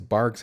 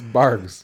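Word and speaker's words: Barq's: I think it's Barg's has bite. – Barg? – Look it up. I'm almost. There's no way it's Barks.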